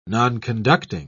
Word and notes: nonconducting: British English

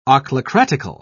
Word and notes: ochlocratical: British English

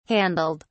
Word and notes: handled: British English

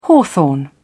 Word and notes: hawthorn: British English

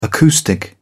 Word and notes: acoustic: British English

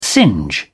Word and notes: singe: British English

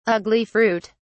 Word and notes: ugli fruit: British English

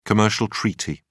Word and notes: commercial treaty: British English

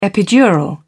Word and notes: epidural: British English

